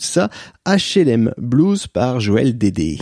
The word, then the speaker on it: Dayde: Par exemple, en 1971, Sophie Macnot chantait Mon bel HLM et en 1973, Joël Dédé, j'ai envie de le prononcer Dédé, ça s'écrit D-A-Y-D-E, Joël Dédé chantait HLM Blues, donc c'est un chanteur français et c'est lui que j'ai choisi.